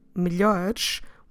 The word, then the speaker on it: melhores: Now that you know how do we write them down, notice that we're dropping, when we're saying on the plural form melhores, we're dropping both es. So both, and I will say now in English, both letter E, or in Portuguese letra E, we're dropping when we pronounce it. We're saying melhores, melhores. And that's why it might be slightly difficult to understand. Also, the LH in the middle is the sound L. L. Melhores.